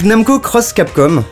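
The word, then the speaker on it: Namco x Capcom: Donc Namco Cross Capcom, avec un X mais qu'on prononce Cross pour un crossover évidemment.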